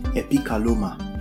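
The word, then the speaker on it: epikaloma (ἐπικάλυμμα): When the Bible talks about cloak, it's pronounced in the Greek epikaloma. The word cloak is pronounced in the Greek epikaloma, which means a covering.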